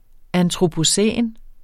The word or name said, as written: antropocæn